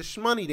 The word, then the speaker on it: Shmoney: Or is the SH silent? Like, is this the Hindu pronunciation of money? What is this? So I found out whenever the Olympic, that was the FIBA World Cup basketball team. They won the championship. And after they won the championship, they all started doing the Shmoney dance. So when I was watching on TV, somebody said the Shmoney dance. And they were like, oh, you pronounced it correctly. And I was like, oh, it's Shmoney.